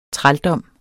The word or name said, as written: trældom